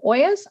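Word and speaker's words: olla: So OYAs are it's Spanish. – Oh, OYA, sorry. – Yes, it comes from Spanish. So the double L's is pronounced like a Y.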